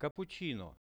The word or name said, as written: cappuchino